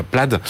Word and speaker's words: Plaid: donc la Plaid. Euh, c'est comme ça qu'on prononce euh, en anglais, ça s'écrit Plaid.